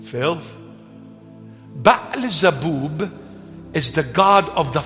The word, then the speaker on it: Beelzebub: Now, Beelzebub, that's an English pronunciation. It's Baalzebub.